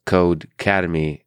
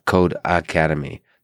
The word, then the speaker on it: Codecademy: By the way, that's spelled Codecademy. It's not Code Academy. There's no A. It's Codecademy. Codecademy.